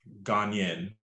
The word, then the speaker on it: Ghanaian: How do you say it? Ghanaian? – Ghanaian. – Ghanaian culture.